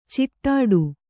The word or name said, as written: ಛಡ್ಡೆ